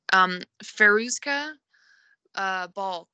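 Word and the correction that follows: Fairuza Balk: who played Faruza Balk, yeah.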